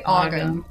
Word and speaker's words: Ogham: Ogham? How do you say that? – I say Ogham.